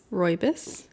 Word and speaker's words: Rooibos: Vanilla Robios. – Rooibos. – Rooibos. – I think, actually, I could be telling you that wrong. – I don't know how to say that. Vanilla something. It's spelled Rooibos. – I think it's rooibos.